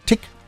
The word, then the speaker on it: Tich: T-I-C-H wird Tick ausgesprochen. Ja, so wie Tick-Tack.